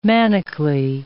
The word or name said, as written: manically